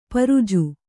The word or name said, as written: ಪರಿಜು